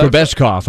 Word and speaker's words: Grebeshkov: Is it Grebishov? From the Grebeshkov, I think is how you say it.